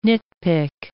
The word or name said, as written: nitpick